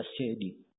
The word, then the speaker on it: Sa'di: Ya, di dalam membacanya kalimat apakah dibaca Sa'di atau dibaca Sidi, kalau penduduk negeri beliau orang-orang Al-Qasim itu membacanya As-Sidi.